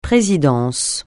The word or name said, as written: présidence